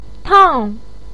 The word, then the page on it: tang4: pinyin